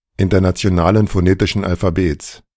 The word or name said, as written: Internationalen Phonetischen Alphabets